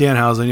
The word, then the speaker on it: Danhausen: Danhausen. Is that how you pronounce it? – Danhausen, yeah.